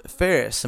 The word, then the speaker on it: Phares: It's just my name, Scott Joseph Ferris. And the last name is spelled P-H-A-R-E-S. Again, the full spelling is S-O-C-T.